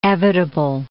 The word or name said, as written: evitable